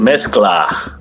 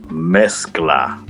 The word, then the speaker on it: mezcla: Mezcla. – That's right. Say, all right. Repeat it one more time, nice and slow, for the gringos out there. Come on. – Mezcla. – Very good. Mezcla.